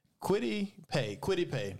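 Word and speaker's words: Kwity Paye: Quiddy Pay.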